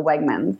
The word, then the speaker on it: Wegmans: It's Wegmans. So Wegmans.